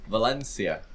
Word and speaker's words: Valencia: Valencia.